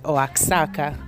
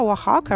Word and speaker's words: Oaxaca: Eh, ed eccoci qua, quindi sono arrivata ieri a Oaxaca, che però si scrive Oaxaca, che però si legge Oaxaca